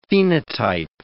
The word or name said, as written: phenotype